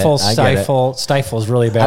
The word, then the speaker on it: Stifel: Stifel and Nicholas, and now just known as Stifel. But yeah, it's it doesn't look like it should be pronounced that way, but it's a German. It's German.